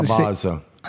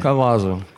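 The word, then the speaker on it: Cavazo: Now, Carlos, I don't know how Cavazzo. – I never know how to say his last name. – Cavazzo, quite right.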